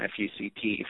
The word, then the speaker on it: FUCT: it's the letters F-U-C-T, which obviously if you pronounce that phonetically sounds like the past tense of a naughty word, although the somewhat tongue-in-cheek Eric Brunetti, the founder of it, says, no, you just pronounce it as an acronym, F-U-C-T, for friends you can't trust.